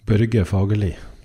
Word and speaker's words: Børge Fagerli: Actually, maybe you can do a public service announcement and just like, can you say your name like how you would actually say it in Norway? – Børge Fagerli.